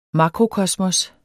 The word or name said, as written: MAKROKOSMOSSETS